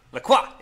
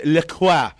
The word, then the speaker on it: LaCroix: LaCroix. It's a French word. It's pronounced LaCroix. No, listen, here's the thing. You are 100% correct because I call it that forever. However, the website does say LaCroix, and it annoys the hell out of me.